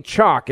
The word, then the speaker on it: Choq: Now, I've been taking Chalk, it's spelled C-H-O-Q, and for 2 weeks now, this is what I've been doing.